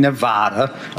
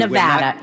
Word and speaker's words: Nevada: Point in Nevada. Okay. – Nevada. It's Nevada, sir. Nevada. Please say it correctly. It's Nevada.